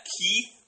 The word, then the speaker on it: Keef: K-E-E-F. Keith. Spelled to rhyme with beef.